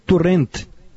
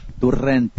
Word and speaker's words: Torrent: Eu jogou, queria só que perguntar para o Gabardo, para ele me confirmar qual é a pronúncia correta em catalão do novo técnico do Flamengo. – Dominic Torrent. Dominic Torrent. – Torrent. – Torrent.